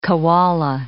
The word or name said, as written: koala